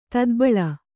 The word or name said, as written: ತದ್ಬಳ